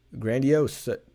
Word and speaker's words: grandiose: grandois? Personalities or grandois? How do you say that? Grandiose.